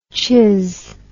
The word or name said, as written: chizz